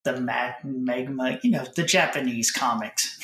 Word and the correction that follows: manga: Manga. – Manga.